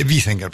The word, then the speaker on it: Wiesinger: Wiesinger, para pronunciarlo como corresponde.